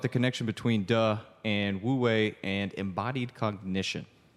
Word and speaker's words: Wu Wei: Did I pronounce that right? – Wu Wei. Yeah.